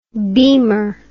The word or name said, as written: beamer